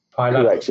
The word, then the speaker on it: Pi Labs: Pi Labs.